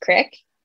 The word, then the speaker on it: creek: I mean, we pronounce creek is pronounced crick.